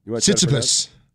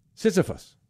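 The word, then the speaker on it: Tsitsipas: Sisyphus. – Sisyphus.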